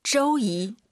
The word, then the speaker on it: 周一: 周一. – 오유 발음은 어예요. 절대 쪼쪼 하지 마세요. 쪼, 쪼이 이렇게 발음하는 거예요.